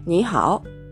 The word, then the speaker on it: 你好: なので、発音としてはですね、発音のポイントとしては、にはおという感じになります。